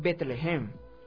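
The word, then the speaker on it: Bethlehem: Belén, en realidad, el nombre pronunciado correctamente es Betlehem, y se traduce como Casa del Pan.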